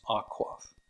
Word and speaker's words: Waqf: You probably have seen it spelled W-A-Q-F. Or as it's pronounced, Aquaf.